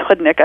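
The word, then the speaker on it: chłodnik: Well, the summer beet soup, which is chudnik, pronounced chudnik,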